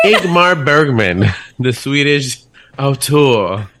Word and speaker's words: Ingmar Bergman: Ingmar Bergman, yes.